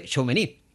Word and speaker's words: Tchouaméni: Creo que es el nombre, se dice Tchouaméni, que es jugador del Real Madrid.